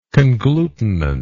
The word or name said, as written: conglutinant